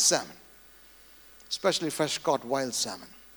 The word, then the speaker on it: Salmon: But in Matthew 1 verse 5, there's a man's name in Hebrew, S-A-L-M-O-N, the L is not silent.